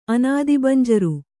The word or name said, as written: ಅನಾದಿಬಂಜರು